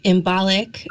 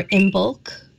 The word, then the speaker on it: Imbolc: Groundhog's Day, February 2nd, is actually Imbolc or Imbolc or Imbolc, also called Oimilk. There's different ways of pronouncing it and different versions of it, but essentially it's February 2nd, and it's the first festival of spring, and it's the first stirrings of spring.